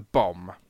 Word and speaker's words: Bombe: Now, it's spelt B-O-M-B-E. For the rest the of the bomb. War... Sorry, go on. – Sorry, I just said that in a slightly French accent because I had an E on the end.